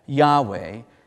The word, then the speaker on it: Yahweh: And we usually pronounce that Yahweh, and it means I am.